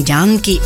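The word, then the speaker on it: Janaki: Ja, ze staat Janky, maar ze wordt gewoon Janky uitgesproken. Op met hits van bijvoorbeeld Lata en Asha Bhosle.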